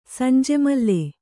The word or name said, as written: ಸಂಜೆಮಲ್ಲೆ